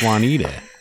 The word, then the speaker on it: Juniata: Juniata Juanita. Park, that's how. No, south of Snyder, it's pronounced Juanita.